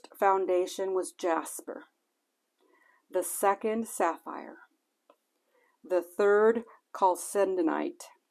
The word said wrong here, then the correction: chalcedony: I think I pronounced that wrong. Chalcedony.